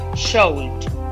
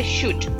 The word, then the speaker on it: should: Cuidado porque hay mucha gente que quiere pronunciarlo should. La L es muda igual que la O. Se le dice should, como si decía S-H-U-D.